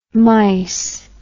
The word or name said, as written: myc